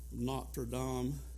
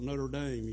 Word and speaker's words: Notre Dame: You talk about Notre Dame, the correct pronunciation, but here we call it Notre Dame. You hear them talk about playing football, Lionel, play Notre Dame. That's not really the way they pronounce it in France.